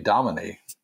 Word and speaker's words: Domine: And then I learned from Ted Rockwell from our last podcast together this summer that it is actually Astronomy Domini. – Yeah, I was just, see, I said Domine, and then I was like, that's not how you say it.